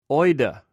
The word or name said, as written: oida